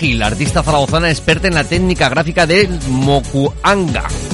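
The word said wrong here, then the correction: Mokuhanga (木版画): Eh, lo he dicho bien, Mokuhanga. – Mokuhanga.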